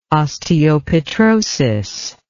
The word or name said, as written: osteopetrosis